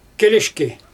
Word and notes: kelishki: Xyzyl

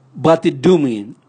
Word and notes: bwati dumwi: Raga

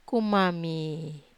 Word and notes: kumami: Wamesa

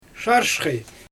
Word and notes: ʃarʃxaj: Xyzyl

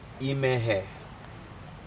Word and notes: imehe: Aneityum

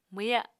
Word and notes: mwya: Apatani

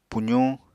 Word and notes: puzuñ: apatani

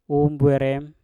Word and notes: unbwerem: mpur

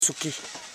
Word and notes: suki: su.ki

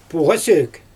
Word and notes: puGa söök: Xyzyl

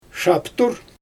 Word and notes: ʃaaptur: Xyzyl

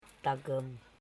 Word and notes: dagim: da.gim

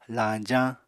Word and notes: lañjañ: apatani

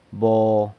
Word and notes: bo: San Lucas Quiaviní

